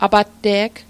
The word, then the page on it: apatte'g: apatte'k; Mi'gmaq